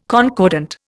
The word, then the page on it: concordant: Indian